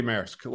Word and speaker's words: MRSK: It's got to be Maersk, like the company.